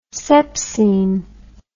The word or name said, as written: sepsine